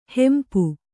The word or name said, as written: ಹಂಪಿ